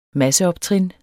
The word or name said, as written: masseoptrin